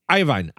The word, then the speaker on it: Iovine: I think it's Iovine or something. But he's a legendary record producer. – It's Iovine.